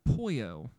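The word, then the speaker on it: Poio: The first I'd like to talk about is a spot that opened up about 6 months ago, and it is called Poyo. So it's P-O-I-O with the long O symbol on top. So it's a Mexican chicken joint,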